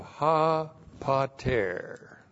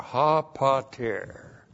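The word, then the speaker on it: ha pater (ὁ πατήρ): And then we have ha pater. This is a definite article, ha, and then p-a-t, long e-r, ha pater.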